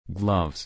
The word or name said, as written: gloves